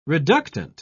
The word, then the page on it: reductant: British English